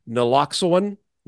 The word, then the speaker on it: Naloxone: Naloxone. – Yep. – Okay. Scissoring. Flumoxazinil. Okay. Or epinephrine. So this is required at bars and restaurants starting 2024. – Naloxone.